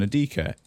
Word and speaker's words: Adika: Turns out, hey, other people, you can find me at at Jordan Adika, A D I K A.